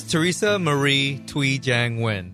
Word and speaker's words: Thuy Trang: It's Thuy Trang.